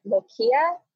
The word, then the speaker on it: lochia: Is it lochia? L-O-C-H-I-A.